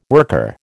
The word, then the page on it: worker: American English